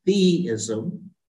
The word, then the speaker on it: theism: and so we're all in this place where without God providing meaning, and the word there, Skip, is theism, which rhymes with deism, but theism, like theology, tells us about a personal God.